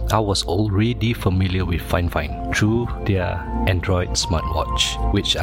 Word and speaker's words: FIFINE: It is pronounced FIFINE, not 15, or FIFINE.